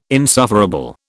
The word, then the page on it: insufferable: British English